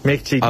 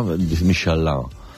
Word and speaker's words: Michelin: Michelin. A ver, aquí en la Península Ibérica hacemos Michelin.